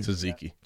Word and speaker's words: tzatziki: Extra tzatziki sauce. Is that how you say it? – Tzatziki.